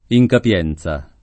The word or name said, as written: incapienza